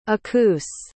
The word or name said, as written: acoose